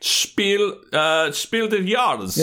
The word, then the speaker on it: Spiel des Jahres: Player tile placement city builder game, which was a Spiel des Jahres winner in 2017. I don't know if I said that right. Probably didn't. – Spiel des Jahres.